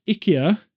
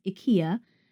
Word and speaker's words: IKEA: So, IKEA, which we've now learned, the Swedish say IKEA. – Yeah. The correct pronunciation is IKEA.